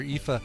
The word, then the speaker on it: Aoife: Aoife, the Irish pronunciation of the word spelled A-O-I-F-E.